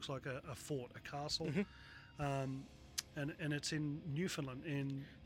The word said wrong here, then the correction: Newfoundland: say it right, God, for God's sake, say it right. – Oh, sorry, Newfoundland. – No, she'll kill both of us. – Newfoundland. – Newfoundland. – Newfoundland. – So she always says, like, understand Newfoundland.